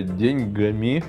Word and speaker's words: деньгами: И я хочу своими э, деньгами про... Подожди, ты сейчас поставил ударение на каждый слог, потому что не знал, на какой надо ставить.